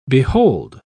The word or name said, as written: behold